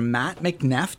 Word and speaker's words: Mat McNeff: Okay, we are here, very fortunate to have Mr. Mat McNeff.